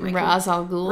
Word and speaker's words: Ra's al Ghul: Ra's al Ghul. – Ra's al Ghul.